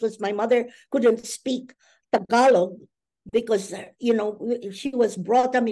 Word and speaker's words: Tagalog: I'm presuming I'm going to mispronounce this. Tagalog, or Dialogue, yes.